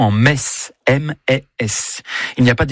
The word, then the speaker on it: Metz: Le meilleur moyen de mémoriser la prononciation correcte de la ville de Metz est certainement de se rendre au stade Saint-Symphorien, où vous répéterez jusqu'à plus soif. Ici, c'est Metz.